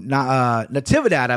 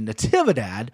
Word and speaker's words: Natividad: Natividad, I believe is how you pronounce his name, actually. Natividad.